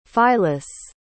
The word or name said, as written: phylis